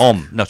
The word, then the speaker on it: homme: Om. – Om, is it? – H-O-M-M-E, but you don't pronounce the H.